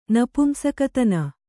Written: ನಪುಂಸಕತನ

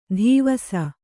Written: ಧೀವಸ

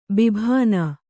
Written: ಬಾಬೂನ

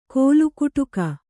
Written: ಕೋಲುಕುಟುಕ

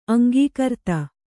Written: ಅಂಕಕಾರ್ತಿ

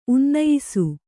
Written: ಉನ್ನಯಿಸು

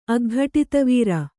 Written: ಅಗ್ಘಟಿತವೀರ

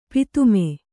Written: ಪತಮ

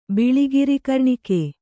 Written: ಬಿಳಿಗಿರಿಕರ್ಣಿಕೆ

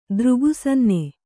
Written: ದೃಗುಸನ್ನೆ

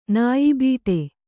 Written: ನಾಯಿಬೀಟೆ

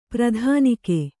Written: ಪ್ರಧಾನಿಕೆ

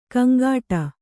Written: ಗೊಂಗಟೆ

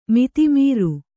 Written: ಮತಿಮರೆ

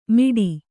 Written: ಮಿಡಿ

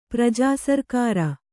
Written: ಪ್ರಜಾಸರ್ಕಾರ

